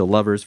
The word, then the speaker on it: LVRS: So Lovers, for those of you listening, is spelled LVRS.